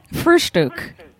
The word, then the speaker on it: Frühstück: Frühstück.